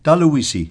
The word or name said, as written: taluisi